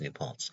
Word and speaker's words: New Paltz: I'm absolutely sure it's pronounced New Paltz.